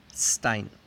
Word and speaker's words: Stein: Vemos la palabra de diversión, Spaß, S-P-A-S-Z. Número 9. Las combinaciones SP y ST. En estas dos combinaciones la S cambia su pronunciación a sh. El ejemplo anterior, spas. O otra, el deporte, S-P-O-R-T, sport. O hablar, sprechen. Un ejemplo con ST sería Stein, piedra. S-T-E-I-N, Stein.